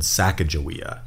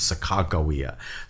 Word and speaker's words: Sacagawea: We often hear her name said Sacagawea. Mm-hmm. But the way her name was written in the journal was either with K's or hard G's, Sacagawea.